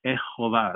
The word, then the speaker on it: Jehovah: He's Jehovah, the Yod, the He, the Vav, the He, his Hebrew, his sacred name, the Jehovah, that's how you say it.